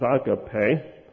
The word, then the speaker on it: agape: The Greek word for love in Jude 21, I always said agape, but I noticed in some of my references the pronunciation is agape.